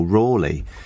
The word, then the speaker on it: Raleigh: I blame Sir Walter Raleigh, or Rawley, as I was told you have to pronounce his name.